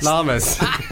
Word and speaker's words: llama: Llamas? How do you... – Llamas. – Llamas. – Llamas, a much, much better pronunciation.